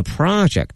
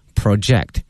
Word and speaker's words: project: 刚才我们讲到它可以作为名词，那么同时呢也可以作为动词来使用，但是大家一定要切记它的发音，从名词的 project 变成动词的 project。